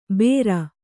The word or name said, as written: ಬೇರ್